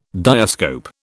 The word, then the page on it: diascope: British English